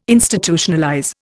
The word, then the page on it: Institutionalize: Indian